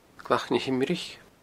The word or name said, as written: glacnahimrich